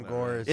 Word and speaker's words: Gores: G-O-R-E-S. So I may not be pronouncing Tom Gores.